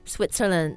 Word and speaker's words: Switzerland: Switzerland.